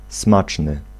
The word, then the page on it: succulent: /sy.ky.lɑ̃/